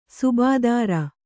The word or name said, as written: ಸುಬೇದಾರ